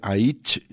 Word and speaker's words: Aitch: Le hemos visto con gente como The Streets y ahora decíamos el nuevo capítulo es Aitch, se llama, así se escribe Aitch y en realidad es como según él se pronuncia su apellido que empieza con H.